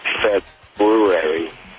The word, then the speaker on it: February: Yes, I believe the correct pronunciation is February.